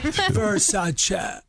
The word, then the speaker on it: Versace: Versace. Yeah. Versace. It's like you're still saying Che, like A-Y, and I don't think it is.